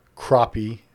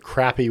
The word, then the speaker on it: crappie: She caught her first crappie. I was told crappy was the wrong pronunciation for that.